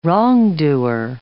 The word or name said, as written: wrongdoer